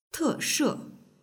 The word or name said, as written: teshe